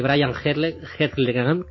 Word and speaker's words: Brian Helgeland: Pero en cuanto al tema de la historia, creo que este Brian Helgeland, no sé si lo he dicho bien, sí.